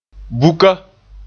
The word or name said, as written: buka